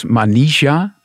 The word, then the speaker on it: Manisha: Dan eh, gaan we even zitten: Het is Manisha, zo spreek je het uit.